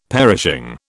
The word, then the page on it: perishing: British English